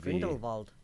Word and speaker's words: Grindelwald: It's pronounced with a V. – Grindelwald.